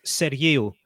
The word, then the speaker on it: Sergiu: Yeah, it's a Greek Cypriot name, and it's actually pronounced Sergiu. But no one said Yil, apparently, but no one ever says that, obviously. So it's become Sergiu. Just people look at it and say Sergiu, and I'm all right with that.